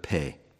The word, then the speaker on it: Peh: And this section, as I've said, is labeled or titled with the Hebrew letter Peh. And that's the way I pronounce it. At least that's the way I was taught to pronounce it.